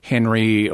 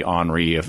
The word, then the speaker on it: Henri: named Henry, or maybe Henri, depending on how you want to pronounce it, Denzinger.